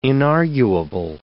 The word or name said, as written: inarguable